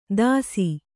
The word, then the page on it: ದಸಿ: dasi